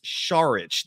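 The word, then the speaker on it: Šarić: Saric, by the way, I looked up his proper pronunciation for his last name. It's Dario instead of Super Mario. It's Super Dario, but his last name is not Saric. It's Saric. The S is actually pronounced like an SH.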